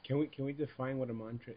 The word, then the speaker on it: mantra: So we already explored that when we did So in a word like mantra, the first syllable will be emphasized, mantra, mantra, because it's built into the language that if you have a compound consonant, that first syllable will be emphasized, mantra.